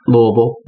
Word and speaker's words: Louisville: Louisville. – That's how you pronounce it. – That's right.